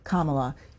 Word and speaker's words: Kamala: Congressman, the vice president pronounces her first name Kamala. You mispronounce it.